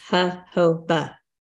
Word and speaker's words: jojoba: It's, I looked at the pronunciation, and it's jojoba.